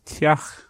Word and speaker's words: teach: For example, house is teach. This is spelled T-E-A-C-H. The E is not pronounced, it just makes the T into a T sound, and the C-H is a H sound.